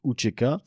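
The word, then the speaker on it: UÇK: donc l'UCK ou Tcheka parce que le, CCD se prononce Tché, donc Ucheka euh, qui est en fait un, espèce d'organe représentatif qui très vite sera d'ailleurs considéré comme une euh, organisation terroriste.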